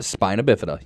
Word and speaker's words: spina bifida: Spina bifida, yes. – Okay. I didn't think I was going to say that right.